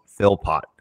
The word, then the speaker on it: Philpott: Ladies and gentlemen, I want to introduce to you Tom Philpott from Johns Hopkins University.